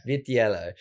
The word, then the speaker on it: Vitiello: Vitiello.